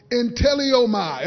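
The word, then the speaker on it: entellomai (ἐντέλλομαι): The word command in the Greek, it is pronounced "entellomai." Everybody say "entellomai."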